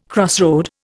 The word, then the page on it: crossroad: Indian